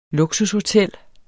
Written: luksushotel

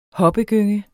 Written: hoppegynge

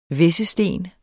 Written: hvæssesten

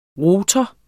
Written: rotor

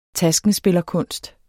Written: taskenspillerkunst

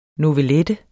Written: novellette

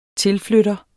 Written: tilflytter

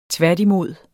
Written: tværtimod

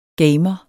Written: gamer

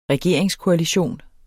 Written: regeringskoalition

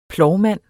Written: plovmand

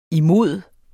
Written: imod

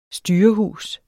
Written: styrehus

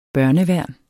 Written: børneværn

